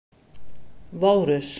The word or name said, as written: Walrus